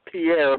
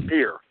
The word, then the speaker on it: Pierre: and most people call it Pierre, but it's pronounced Pierre.